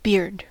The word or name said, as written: beard